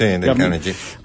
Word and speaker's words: Dale Carnegie: Otro tiene un libro para recomendar, yo se lo decía a Pedro, no sé cómo se pronuncia, Dale Carnegie, ¿es? – Sí, Dale Carnegie.